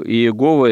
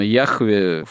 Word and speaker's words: Яхве: Но, скорее всего, Иегова – это такой искаженный, ошибочный вариант произнесения имени Божьего. Именно Яхве фактически является наиболее, видимо, точным вариантом этого произношения, древнейшего еще.